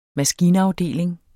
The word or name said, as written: maskinafdeling